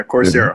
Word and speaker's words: Coursera: They have a course on, help me the pronunciation here, Coursera, right? – Yeah, Coursera.